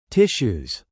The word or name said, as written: tissues